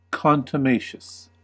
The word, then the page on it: contumacious: American English